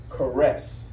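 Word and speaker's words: charis: For the word for grace, you're going to come up with the Greek word C-H-A-R-I-S. C-H-A-R-I-S. And guess how it's pronounced? If I read the pronunciation right, You know how you pronounce it? Listen to this. Is everybody ready? – Yes. – Caress. So when you, you know what I'm talking about? When you caress something, you caress it.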